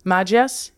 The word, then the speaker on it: Majlis: And it sounds like there was the Maji. Am I pronouncing Majlis. – That correct? Majlis.